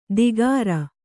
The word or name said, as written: ದಿಗರ